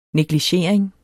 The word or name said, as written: negligering